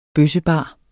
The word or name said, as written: bøssebar